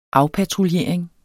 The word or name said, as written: afpatruljering